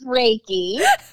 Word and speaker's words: Reiki: Oh, it's, Re- it's Reiki.